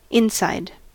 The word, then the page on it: inside: American English